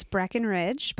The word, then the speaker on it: Breckenridge: It's Breckenridge, but that's my handle for Instagram.